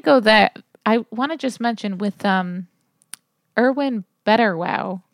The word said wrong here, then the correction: Brettauer: Brettauer. Yeah. That is not how you say it. Brettauer, not Betterwow.